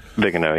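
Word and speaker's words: Viganò: You say Vigano, is that how you say it? – Vigano, yes. – Vigano presented it to Pope Francis,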